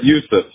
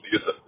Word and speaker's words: Yusuf: Yusuf. A lot of people say Yosef. That's fine. No, Yusuf.